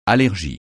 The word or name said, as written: allergie